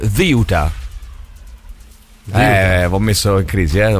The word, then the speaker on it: Ceuta: Come se ci fosse il TH inglese, Ziuta. Ziuta. Con l'accento rigorosamente sulla prima sillaba, ragazzi.